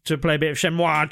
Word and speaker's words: Shenmue: Shenmue, with a soft moo on the end, is apparently the acceptable pronunciation, just if anyone's in doubt, but Shenmue will ever be the way that we pronounce it.